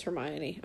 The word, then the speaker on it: Hermione: Like I like was like, oh, that's how you pronounce Hermione.